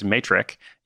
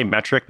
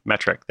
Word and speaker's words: metric: And they were like, Chris, you know, do you mean to say metric? Because you keep talking about matrix, like the matrix. Like, go and check your matrix. And like, that's not correct. So then I was like, great, all this time I've been saying it wrong. Okay, metric, there it is.